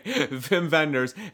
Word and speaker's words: Wim Wenders: I always say Wim Wenders. – Wim Wenders, because but it's pronounced like a V.